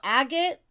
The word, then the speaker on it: agate: So agate is... And that's the proper way to say it, agate.